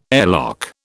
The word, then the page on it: airlock: British English